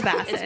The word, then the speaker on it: Bassett: While you say that, I pronounced his name wrong the last time. It's Joshua Bassett. – It is actually Bassett, so we were just trying to be bougie. – It's Bassett, apparently.